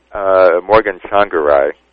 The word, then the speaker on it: Tsvangirai: How do you pronounce his name again? – Morgan Tsvangirai. – Tsvangirai, okay. – It doesn't look like it's how it's spelled.